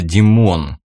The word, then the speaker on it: Димон: Вернее, представители народа Димон, с ударением на букву О.